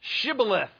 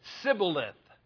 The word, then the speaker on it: Shibboleth: They said unto them, verse 6, say now, Shibboleth. And he said, Sibboleth. Do you see the difference in the pronunciation? For he could not frame to pronounce it right. In other words, the Ephraimites could not say the sh sound.